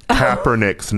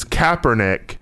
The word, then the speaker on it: Kaepernick: This person has been tweeting at us nonstop, apparently, about how we have been pronouncing Colin Kaepernick's name incorrectly. It is Kaepernick, and apparently we've been saying Kaepernick, and it really turned this person off.